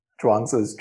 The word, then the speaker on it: Zhuangzi: Zhuangzi. Zhuangzi. That's the Chinese pronunciation.